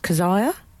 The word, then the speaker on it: Kezia: Is it Kezia Dugdale or Kezia? – I think the producers have said it's pronounced Keziah.